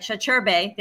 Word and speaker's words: Sacirbey: Sacirbey, there we go, for joining us on the Move the Stairs podcast.